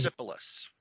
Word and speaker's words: Persepolis: Persepolis? – Yes, Persepolis.